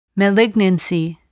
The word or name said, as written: malignancy